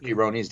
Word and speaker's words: Peyronie's: And the other thing that I was thinking about too, because I know that I may not be pronouncing this correctly, but is it Peyronie's, which is like the curvature, right? – It's called Peyronie's disease, yeah.